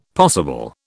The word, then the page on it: possible: British English